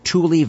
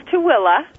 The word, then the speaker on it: Tooele: Am I pronouncing it correctly? Tule Valley? – It's Tooele. – Tooele. pronounce it Thule. That's what it's spelled.